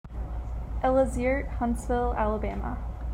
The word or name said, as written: Ella Zierdt